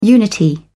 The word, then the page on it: unity: British English